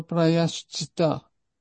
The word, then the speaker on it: prayaschitta: Toi, comme là, il y a le mot, là, dans le point 2.3, prayaschitta. Ben, le ta, là, faut le prononcer. On va pas dire prayaschitta. Et oubliez le A, il faut le prononcer celui-là. Mais dans le cas de Prahlad Maharaj, on saute les A.